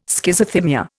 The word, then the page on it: Schizothymia: Indian